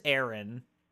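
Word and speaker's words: Aron: Yeah, with an A. So it's A-R-O-N, but I believe mm-hmm. it's pronounced Aaron.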